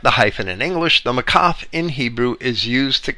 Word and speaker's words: maqaf: And that little dash is called a makaf. That's spelled M-A-Q-A-F.